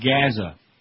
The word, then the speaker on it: Gaza: Or is that Gaza? That's how they say it here, like Mazda and Gaza.